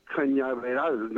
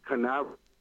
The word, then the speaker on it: Cañaveral: Also, I never knew until I was an adult that it was Cape Cañaveral, not Canaveral. – I never think Nina, but you're absolutely right. That's blown me away for some reason. – Canaveral? Could you pronounce that again, Cape? – Cañaveral means it's part of the cane field, so it's Cañaveral.